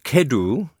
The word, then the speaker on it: cedw: And Martin Mayer from Lancashire wrote in to say CEDW, which I think is pronounced Kedw, is the Welsh for mustard.